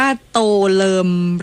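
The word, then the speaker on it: Tô Lâm: อ่านว่าโตเลิมหรือโตลัมคะโตลัมครับผมอยู่ที่เวียดนามเขาเรียกท่านโตลัมครับ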